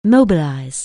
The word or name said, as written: mobilize